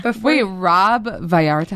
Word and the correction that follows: Valletta: How do you say his name? – Valletta. – Oh, I made him like, I don't know what I made.